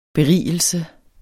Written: BERIGELSEN

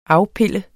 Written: AFPILLER

